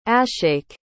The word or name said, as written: ashake